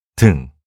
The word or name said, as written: 등